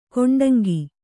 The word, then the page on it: ಕೊಂಡಂಗಿ: kannada